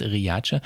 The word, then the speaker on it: Riace: Man könnte denken Riace, aber es heißt Riace.